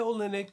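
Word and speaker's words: Olynyk: Yeah, Olenek.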